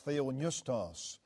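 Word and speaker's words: theopneustos (θεόπνευστος): And that word is pronounced theopneustos.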